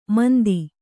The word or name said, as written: ಮಂದ